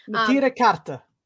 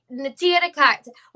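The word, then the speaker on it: Neturei Karta: But I mean, the Netra Karta, and I'm so terrible about pronouncing that. – Natira Karta. – Natira Karta.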